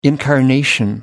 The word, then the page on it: incarnation: American English